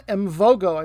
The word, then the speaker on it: Mvogo: I've also got a very good goalkeeper, Ivan Mvogo, I'm going to pronounce it, M-V-O-G-O.